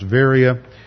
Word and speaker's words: Berea: He landed in Neapolis, then he went to Philippi, then he went to Berea, which they pronounce Varia, and he went to those cities in Macedonia.